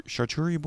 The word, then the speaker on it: charcuterie: So I thought, oh, is this supposed to be like a little like chartur- charcuterie board? How do you say that? – Charcuterie. Charcuterie. Charcuterie.